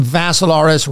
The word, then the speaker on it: Vassilaris: That's Dimitri with three I's, D-I-M-I-T-R-I. Last name Vassilaris rhymes with Asilaris, but you add a V.